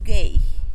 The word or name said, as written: geijy